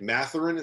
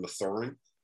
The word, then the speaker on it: Mathurin: It's Benedict Mathurin instead of Mathurin.